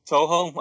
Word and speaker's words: Toho: And we have a semi-editor on the website who links, I guess it's Taoho. That's a big one. I don't know if I'm pronouncing it right. – I think it's Toho. – Toho. Okay.